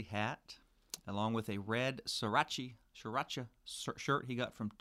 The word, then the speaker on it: Sriracha: Did I say that right? Sriracha? – Sriracha.